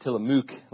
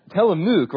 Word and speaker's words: Tillamook: And I felt compelled to tell you that because I pronounced it Tillamook last time. And I just wanted you to all know I know it's pronounced Tillamook now because I went home and told my wife.